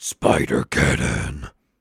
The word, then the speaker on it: Spider-Geddon: to correct your pronunciation. It's pronounced Spider Geddon.